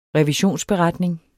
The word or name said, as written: revisionsberetning